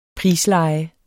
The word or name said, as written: prisleje